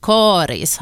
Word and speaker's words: Karis: Rekommendationen om långt A, Karis, har funnits sedan början av 1960-talet, och för det så fanns det faktiskt inte uttalsrekommendationer överhuvudtaget.